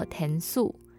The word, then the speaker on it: 帮忙: 媳妇她的客语呢就叫做醒 Q，然后帮忙的客语读作填素，也是一个还蛮特别的词汇，可以稍微记下来。